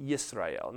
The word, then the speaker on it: Yisrael: The way you'd pronounce that in the Hebrew, you'd have a Y at the beginning of it, Yisrael.